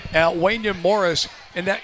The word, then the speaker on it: Wanya: But according to Mark Raimondi, and by the way, he gave us a pronunciation, it's Wanya. – Wanya. – Not Wanya, but Wanya.